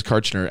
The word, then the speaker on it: Karchner: True story, my last name is Karchner, and I'm not even kidding. When I went to ninth grade baseball tryouts, the head coach, he's like, next up, Krochner.